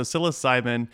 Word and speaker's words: psilocybin: Does it start with an S? But it's actually going to start with a P here, right? So psilocybin is going to be the psychedelic psychoactive compound that is produced in many species of mushrooms.